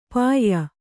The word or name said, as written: ಪಾಯಿ